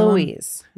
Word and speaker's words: Eloise: Eloise.